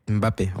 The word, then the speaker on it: Mbappé: Mbappé, voilà.